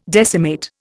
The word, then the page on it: decimate: Indian